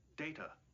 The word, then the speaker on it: Data: because it's his name, it's Data, not Data. – My name. It is pronounced Data.